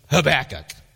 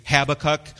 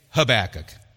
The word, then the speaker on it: Habakkuk: Now, we're going to be walking through the book of Habakkuk, and I pronounce it Habakkuk. Some people might say Habakkuk or Habakkuk, but I say Habakkuk, just because it's easier for me to go.